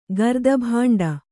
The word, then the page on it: ಗರ್ದಭಾಂಡ: kannada